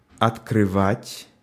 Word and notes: открывать: /ətkrɨˈvatʲ/

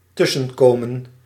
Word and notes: intervenir: [ɛ̃.tɛʁ.və.niʁ]; France

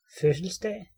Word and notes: fødselsdag: /føsəlsdaː/; [ˈføsəlsˌd̥æːˀ]

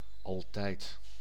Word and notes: altijd: [al.tɛjt]

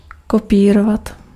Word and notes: copy: English

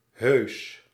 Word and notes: poli: [pɔ.li]; France (Paris)

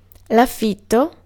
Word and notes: rent: /rɛnt/

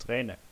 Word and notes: Träne: IPA: [ˈtrɛː.nə]; IPA: /ˈtʀɛːnə/

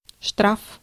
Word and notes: штраф: /ˈʂtraf/

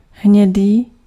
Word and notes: brun: /bʁœ̃/; [bʁæ̃]; [bʁœ̃]

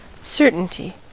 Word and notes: уверенность: /ʊˈvʲerʲɪnːəsʲtʲ/; uverennost

